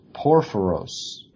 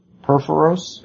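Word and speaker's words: Purphoros: Of Porphyros. – Nope. – Porphyros? – Nope. – Perforos? – There you go.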